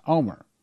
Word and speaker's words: Olmert: Well, you spell it O-L-M-E-R-T, but it's pronounced Omer.